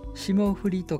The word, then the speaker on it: 霜降: 霜降りと書いて霜降と読むのだそうです。